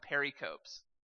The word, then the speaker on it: pericope: and I called them pericopes. Because, you know, you might think that the E is silent on there, but then I learned Greek. And, yeah, it's pericope.